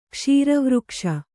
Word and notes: ಕ್ಷೀರವೃಕ್ಷ: kṣīra vřkṣa

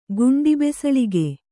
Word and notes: ಗುಂಡಿಬೆಸಳಿಗೆ: Kannada

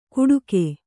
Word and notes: ಕೂಡುಗೆ: Kannada

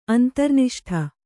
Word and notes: ಅಂತರ್ನಿಷ್ಠ: antarniṣṭha

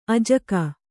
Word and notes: ಆಜಗ: ājaga

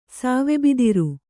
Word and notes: ಸಾವೆಬಿದಿರು: Kannada

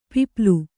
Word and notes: ಪಿಪ್ಲು: piplu; kannada

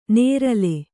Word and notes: ನೇರಲೆ: nērale

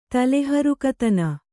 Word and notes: ತಲೆಹರುಕತನ: Kannada

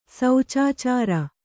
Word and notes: ಸಚಾಚರ: sacarācara

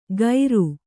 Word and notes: ಖೈರು: Kannada

